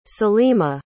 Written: selima

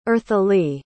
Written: earthily

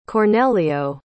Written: Cornelio

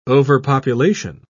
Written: overpopulation

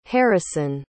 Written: harrison

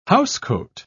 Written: housecoat

